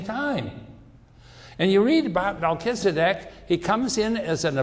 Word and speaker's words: Melchizedek: We pronounce it Melchizedek.